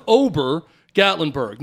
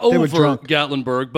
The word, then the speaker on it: Obergatlinburg: And no, I'm not mispronouncing it. The name of this stupid place is O-B-E-R, Obergatlinburg. Not over Gatlinburg, but Obergatlinburg,